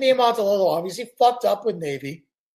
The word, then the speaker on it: Niumatalolo: Ken Niumatalolo, is that right? – Niumatalolo, yep. – I think it's Niumatalolo. Okay, Niumatalolo.